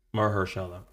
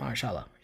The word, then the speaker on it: Mahershala: Mahershala. All right. – Mahershala. Yeah.